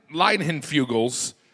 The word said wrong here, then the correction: Leinenkugel: the summer shandy. Mm-hmm. Say it again. Leinenkugel. – Of course. – The Leinenkugel.